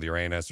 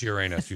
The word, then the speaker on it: Uranus: I mean, Uranus. I know. No, it's Uranus. You said it wrong. You really crap showed that up. – Either one actually works.